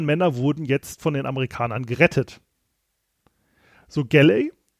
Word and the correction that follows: Gallery: hatte es, Gallery, der wird ausgesprochen, Gallery, hatte sich eigentlich als einen Befehl, also alle U-Boot-Jäger sollten U-Boote einfach zerstören.